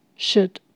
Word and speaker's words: should: L 是不发音的，should should。